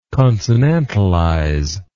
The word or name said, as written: consonantalize